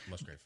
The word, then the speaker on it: Musgrave: How do you say it? – Musgrave.